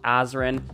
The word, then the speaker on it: Azren: It is at polyglot Azren, that is spelled P O L Y G L O T A Z or Z, depending on your country, R E N. You can also simply search Azren the Language Nerd on whatever your favorite social network is.